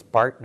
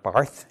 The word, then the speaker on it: Barth: And by the way, it's Basel and not Basil. Okay, so it's Bart, not Barth. It's Basel, not Basil, just in case you want the correct pronunciation for these places.